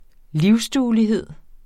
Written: livsduelighed

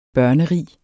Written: børnerig

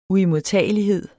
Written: uimodtagelighed

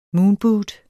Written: moonboot

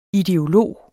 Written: ideolog

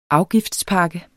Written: afgiftspakke

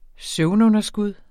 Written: søvnunderskud

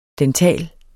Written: dental

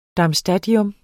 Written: darmstadtium

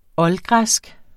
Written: oldgræsk

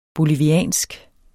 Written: boliviansk

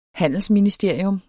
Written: HANDELSMINISTERIUM

